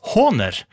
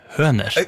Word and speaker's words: Hohner: Hohner er en tysk produsent av trekspill, munnspill og andre instrumenter, uttales Hohner, og ikke med omlyd høner.